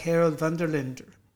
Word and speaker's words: Herald van der Linde: I'm very pleased to have with me in person Mr. Harold van der Linde, which in Dutch sounds something like Harold van der Linde. – No, oh, that's very well pronounced.